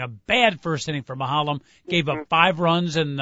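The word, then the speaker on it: Maholm: That's how you pronounce it, right, Mahalam? – Yep.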